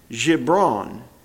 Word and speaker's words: Gibran: The Prophet by Khalil Gibran is the way that that's pronounced.